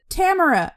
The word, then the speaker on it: Tamora: So, Tamora Pierce, or as I learned like less than a year ago, most people pronounce it Tamora Pierce. I've pronounced it wrong literally my entire life, and it's fine.